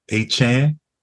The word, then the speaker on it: Achane: We got Devon Achane, and that's the right pronunciation according to him. – Achane? – Chan. – Okay. Because they've been calling him Achane.